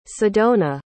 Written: sedona

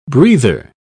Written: breather

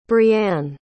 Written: brianne

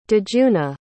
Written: djuna

